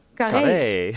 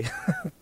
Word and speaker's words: Carré: How do you pronounce the venue? Carré? – Carré. – Carré. Thank you.